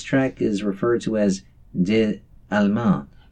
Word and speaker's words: Des Allemands: Katie Hopgood Ray along with Day Ray, Des Allemands from the new album I Dream of Water.